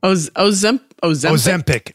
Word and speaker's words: Ozempic: How do you say it? – Ozempic. – Ozempic. I've adjusted a couple letters in there.